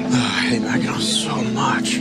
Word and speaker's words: Mackinac: Mackinac Island. – Mackinac Island. – First of all, yeah. – It's spelled, why do they spell it Mackinac when it's Mackinac?